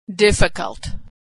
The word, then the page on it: difficult: female